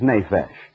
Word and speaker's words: nephesh: was N-E-P-H-E-S-H, the way we would spell it in English and pronounce nephesh.